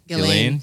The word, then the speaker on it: Ghislaine: Ghislaine? – Ghislaine.